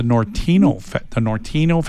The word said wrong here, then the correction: Norteño: Festival, am I saying that right? – Mm-hmm. – That type of music. Norteño, I got to say it right. Norteño Festival. He said he's talking to the mayor, and there's a good chance it's going to come back.